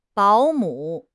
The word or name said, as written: 保姆